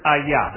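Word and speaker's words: hayah: It's the word ayah. That's how you would pronounce this.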